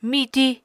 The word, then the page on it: miti: Apatani